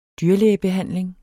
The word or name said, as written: dyrlægebehandling